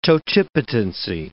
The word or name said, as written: totipotency